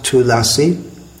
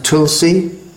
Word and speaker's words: Tulasi: Now the word Tulasi, going on to Tulasi prayers, in Sanskrit it's pronounced Tulasi, in Bengali, well, in Hindi it's pronounced Tulsi, or in Bengali Tulshi, but that's alright when we're singing the Bengali song, but in the Sanskrit prayer that we chant, first of all, it should be Tulasi. Vrindayai Tulasi Devyai.